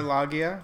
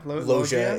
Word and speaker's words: Robert Loggia: Robert Loggia? – Loggia is how it's pronounced.